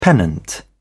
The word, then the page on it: pennant: British English